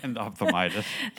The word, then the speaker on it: endophthalmitis: Endophthalmitis.